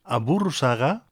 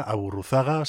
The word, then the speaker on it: Aburruzaga: y en este caso se trata de Xavi Aburruzaga, si lo intentamos pronunciar un poco a la vasca, Aburruzaga, si lo pronunciamos más a la castellana.